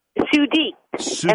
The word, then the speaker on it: Sudiek: It's S-U-D-I-E-K, Sudiek? – Sudeek, and I'm a woman.